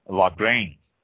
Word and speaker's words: Lagrein: Lagrein. This is spelled L-A-G-R-E-I-N, Lagrein.